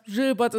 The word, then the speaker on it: Gilbert: Ich hatte gerade hinter mir noch ein Interview mit Gilbert Burns oder wie er mir gesagt hat, Gilbert ist eigentlich richtig, man kann aber beides sagen.